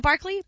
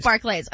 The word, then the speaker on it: Barclays: Is it Barclay? – Barclays. – Like, but is it pronounced Barclay Center? – No, Barclays. No? Barclays. Okay.